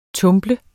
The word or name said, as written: tumble